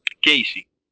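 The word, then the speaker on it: Keysi: Es un arte marcial que se llama, perdón, un sistema de defensa personal que se llama Keiji. Que suena como Keiji and the Sunshine Band, pero se escribe Keisi.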